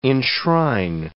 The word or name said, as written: enshrine